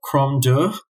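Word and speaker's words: Crom Dubh: Some people say that the Dullahan grew out of the ancient Celtic god, which I believe is pronounced like Cromdu, another Gaelic spelling.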